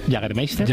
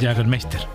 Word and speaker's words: Jägermeister: Jägermeister. – Jägermeister.